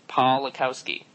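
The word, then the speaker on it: Pawlikowski: Read it. It's phonetically Polakowski. Polakowski. Yeah, boom, boom, boom.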